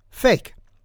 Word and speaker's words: PHEIC: since as an acronym, this would be PHEIC and pronounced fake.